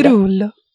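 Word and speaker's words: Cerullo: Cerulo, Cherulo, no sé cómo se dice. – Cerulo. – Cerulo.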